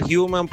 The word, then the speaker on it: Human: Sí, se pronuncia human porque la H en inglés. Human Right Watch.